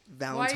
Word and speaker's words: Valentine's: Valentine's. – Why you say it like that? – Happy Valentine's, everybody. – Why you say it like that? – Like what? – Like with the M.